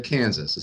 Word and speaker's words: Kansas: It's not the state of Kansas. It's Kansas is the town. Illinois is the state. – All right. Okay. – And you don't pronounce the S. It's silent.